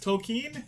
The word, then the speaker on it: Tolkien: This week, we are talking about The Hobbit by an unknown author of Tolkien? Question mark? I don't know how to pronounce that. – Tolkien? – I don't know. – If you hear Neil Gaiman talk about Tolkien, it's always Tolkien.